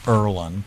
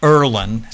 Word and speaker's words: Irlen: There's a fascinating syndrome called Erlen, I-R-L-E-N. – I'm sorry? Called what? – Erlen syndrome, I-R. – How do you spell it? – I-R-L-E-N.